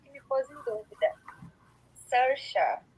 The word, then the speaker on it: Saoirse: O nome dela é Sersha Ronan.